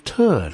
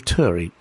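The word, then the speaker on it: Turre: Now, we've got Steve Turr now. Now, I've looked up this. It could be Steve Turr. It could be Steve Turry. People with that surname pronounce it both ways, and I'm afraid Mr. Steve Turr, Turry.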